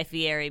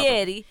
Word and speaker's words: Fieri: This is one of the many reasons why I've done such a 180 on Guy Fieri, because I feel like he... – Please, say his name properly. – Fieri.